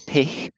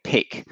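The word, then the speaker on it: Pich: Okay, so my surname is Pick, and it's P-I-C-H. But feel free to call me Pick.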